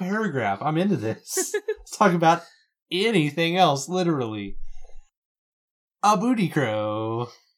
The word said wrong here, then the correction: abudikro: Oh, sorry, I put the emphasis on the wrong syllable. It's abudikro.